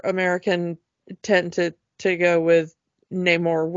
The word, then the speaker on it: Namor: He always pronounces it Namor because it's sin amor without love. So he really does the amor part.